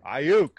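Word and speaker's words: Aiyuk: I mean, if Brandon Ayuk, is that – how do you say his last name? – Ayuk.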